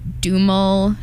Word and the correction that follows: Duhamel: Duhamel. – Sorry, Duhamel.